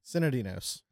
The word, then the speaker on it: Sinodinos: Sinodinos. – Okay. See, I pronounce it too much, and it bothers me. Sinodinos, right? – Yeah. – Sinodinos. – It's exactly not like it's spelled.